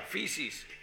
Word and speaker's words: feces: Fisis. – Fisis, claro, eso sería ya coprofagia. Feces. Coprofagia. – Claro, las heces. Es que la palabra heces en inglés se dice feces, con F, feces. Se pronuncia fisis.